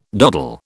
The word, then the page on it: doddle: British English